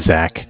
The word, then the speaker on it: Zach: So my name is Zach, spelled Z-A-C-H. – Z-A-C-H, okay. – Calling with Bank of Cardiff. Cardiff is spelled C-A-R-D-I-F-F, as in Frank Frank.